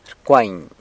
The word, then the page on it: rcwany: sanlucasquiavini